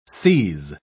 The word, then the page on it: seise: British English